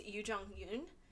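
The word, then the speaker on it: Youn Yuh-jung: I wish I had looked up how to say her name correctly before this, and I apologize to everyone. But it's Yoo Jong-yoon.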